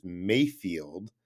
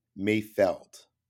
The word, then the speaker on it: Mayfeld: Highlight of my week, I noticed that everyone pronounced Bill Burr's character as Mayfield, but it's definitely Mayfeld.